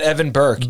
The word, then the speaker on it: Evan Berke: Yeah, at Evan Berke. – Berke is B-E-R-K-E.